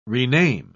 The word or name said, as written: rename